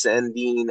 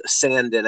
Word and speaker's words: Sandin: I like the first round pick of Rasmus Sandin or Sandin. I've heard it pronounced both ways.